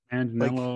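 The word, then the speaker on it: Manganiello: And then like Joe Magniello, is that how you say his name? – Magnello, like, Magnello.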